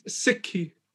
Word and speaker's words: Sikhi: Now, how do you, so it's Sikhi. Am I saying it right? – It's Sikhi.